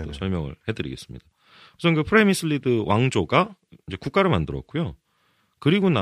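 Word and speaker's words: Přemyslid: 발음 잘 들어보십시오. 프레미슬리드. – 아! 미치겠다 진짜. – 그냥 영어식으로 하면 프레미슬리드. – 네. 예. 근데 정식은 어떻게 된다고요? – 프레미슬리드. – 프레미슬리드? – 예예 비슷합니다. 네. 보통 그냥 프레미슬리드라고 하는데 네네네. 그냥 프레미슬리드라고 하겠습니다.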